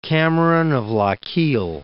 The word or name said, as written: Cameron of Lochiel